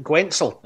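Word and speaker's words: Guentzel: His teammate, Jake, was it Gwentzel, if I've pronounced that correctly? – Yep.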